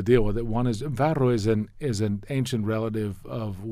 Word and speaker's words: farro: It is slightly ridiculous to say it correctly in Italian, farro. – I think it's fun to say that. – Okay, can say it. – Farro. – There you go.